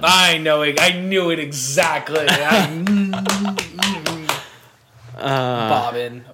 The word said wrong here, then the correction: Boban: Boban. Got it.